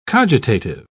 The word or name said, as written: cogitative